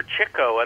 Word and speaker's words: Chico: However, Chico, and that's the correct pronunciation of his name because he chased chicks.